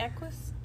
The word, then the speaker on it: Equus: So when you come in the side door at Equus, is it Equus? – It's Equus. Mm-hmm. Yeah, you said it right.